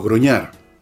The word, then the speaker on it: grognard: O sea, la imagen, si ustedes ven en Google, imágenes de los groñar, pueden poner la palabra grognard, se escribe,